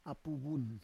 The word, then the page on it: apu vun: Roon